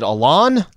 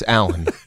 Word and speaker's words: Allen: S-L-I-W-A, just like it looks. – Is it Alon? No, it's Alan. – Yes! Right? Nobody's looking at A-L-L-E-N and going, yeah, I don't know, man.